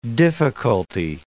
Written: difficulty

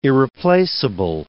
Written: irreplaceable

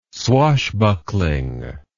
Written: swashbuckling

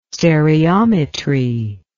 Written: stereometry

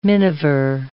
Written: miniver

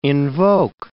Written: invoke

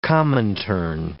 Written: Comintern

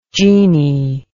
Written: jeannie

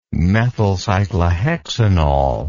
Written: methylcyclohexanol

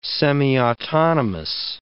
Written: semiautonomous

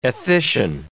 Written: ethician

